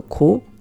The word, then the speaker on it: 고: Utilize the 고 conjunctive and pronounce the 고 like 구 as many natives do.